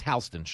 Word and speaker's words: Houston: Like, I mean, in New York, Houston Street is pronounced Halston Street.